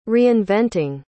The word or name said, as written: reinventing